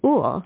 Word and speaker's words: Uhl: And we recently learned that this great benefactor and his wife, Diane, who also recently died, pronounced their name Uhl. It's spelled U-H-L.